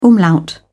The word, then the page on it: umlaut: British English